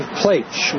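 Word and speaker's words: Pleich: Steve Plaich. Rhymes with H. P-L-E-I-C-H. Plaich. And he's available. – P-I-C-H. P-L-E.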